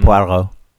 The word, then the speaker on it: Poirot: Yeah, it's a Poirot.